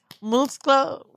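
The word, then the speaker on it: muslo: And then I learned chicken thigh is musclo. What's that? – Look at you, see? – I want no, what is it? – Musclo. Pollo musclo. – You're saying it right. You're saying it right, yes.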